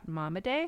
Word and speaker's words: Momaday: Scott Momaday. Am I saying that right? – Yeah.